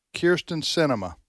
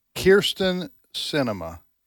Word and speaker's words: Kyrsten Sinema: Kyrsten Sinema. – Right. – Is her name. Kyrsten Sinema.